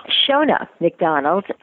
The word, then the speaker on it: Sheona McDonald: Tell me a little bit about Shana McDonald. – Sheona McDonald. Uh, yeah, I said it wrong in the beginning too until she corrected me.